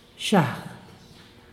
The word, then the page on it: baalgiag: Santiago Matatlán Zapotec